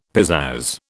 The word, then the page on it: pizazz: British English